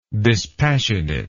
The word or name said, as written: dispassionateness